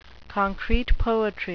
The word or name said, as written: concrete poetry